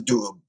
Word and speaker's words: Doubs: And like I said, in the region they call the Doub, you know, I'm not sure if I'm pronouncing that correctly, D-O-U-B-S.